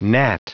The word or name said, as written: gnat